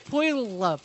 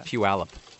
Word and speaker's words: Puyallup: How do you say that? – It's Puyallup. – There's not a yeah in it.